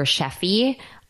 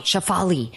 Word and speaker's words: Shefali: I was like, Dr. Sheffy, that's how you pronounce her first name. – Shefali.